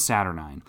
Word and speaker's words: Saturnyne: But uh, what happens is, uh, I believe her name it's it's spelled Saturnyne, uh, Saturn, and then N Y N E. So I think it's Saturnyne.